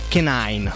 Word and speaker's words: Canine: tak by to mělo být Canine. – Canine bych to četl tež. – Canine, no, píše se to kanine, jak bychom řekli jednoduše tady v Ostravě, a single jménem Power.